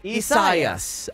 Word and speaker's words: Isaias: Because this is a name of Spanish and Portuguese origin, from the biblical Isaiah. This is to be pronounced Isaias.